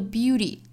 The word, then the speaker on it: beauty: 最后的 beauty，美音的发法里面的这个 t 的发音呢是一个 flap t，因为它是被两个元音夹在中间的哈，所以这个音要浊化，所以发的时候呢是 beauty，很像一个我们说发爆破音的这个音，很像这个音，但是呢要注意这个弹的这个过程 beauty。